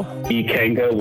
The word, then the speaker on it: Ikenga: It's Ikenga, and you pronounce it how? – Ikenga Wines. – Ikenga, okay. – And my first name is spelled.